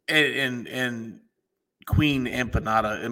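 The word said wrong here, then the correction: Aminata: Am I saying that right? Like empanada, like empanada, like the Emanata. – Emanata. – Emanata. – There's no P in there, huh?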